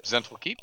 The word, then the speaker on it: Zhentil Keep: Now, turning back to one of my favorite things of all time in the Forgotten Realms, uh, Zhentil, Zhentil Keep is, I'm, I'm pronouncing that correctly, yeah? Zhentil yep, Keep? – Zhentil Keep. – Yep.